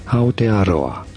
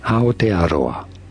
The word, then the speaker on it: Aotearoa: In Maori, it is said as Aotearoa, Aotearoa. In English, it is usually pronounced as Aotearoa, Aotearoa, from Maori, Aotearoa.